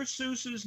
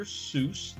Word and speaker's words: Seuss: Dr. Seuss does not rhyme with goose. Dr. Seuss is a German name with a German pronunciation that rhymes with Joyce.